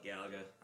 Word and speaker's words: Galaga: Galaga. – The argument that it's for sure Galaga. – But we grew up saying Galaga. So not a person, not a single person pronounced it Galaga.